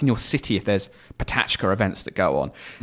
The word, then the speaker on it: Pecha Kucha: It's Patachka is how you pronounce it.